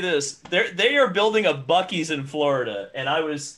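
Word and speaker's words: Buc-ee's: from Boosie's, he says. – Bucky's. – Or Bucky's, that's how you say it.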